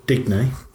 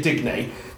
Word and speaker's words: Digne: How do you pronounce the left back? D-I-G-N-E. – Digney. – I'd say Digney. Dean is how the commentator kept on saying, oh, here's Dean. Like, not Dean.